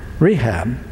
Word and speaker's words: Rechab: Thus, because our father, Rechab, is how you say it.